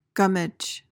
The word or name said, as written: gummidge